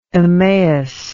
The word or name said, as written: Emmaus